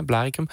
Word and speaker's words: Blaricum: Ze had een villa gekraakt in Blaricum, zeg ik dat goed? Of was ja, Blaricum.